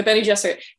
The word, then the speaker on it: Bene Gesserit: I mean, essentially already making them kind of like, you know, if they have any self-determination, they're kind of witchy Benny Garrison, which, by the way, I am going to be mispronouncing everything. And it's okay. You guys can just kind of deal with that. – Yes. Yeah, no, the Bene Gesserit, which, sorry to correct you, the Bene Gesserit.